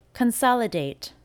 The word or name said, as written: Consolidate